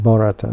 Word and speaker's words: Bharata: That's that Bharata, the three births Bharata.